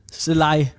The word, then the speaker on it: Zalai: Jamen, det var så Selal. Selaj. Det er svært at udtale det navn, fordi at på ungarsk, så kan I godt udtales L til sidst. Z-A-L-A-I, ikke?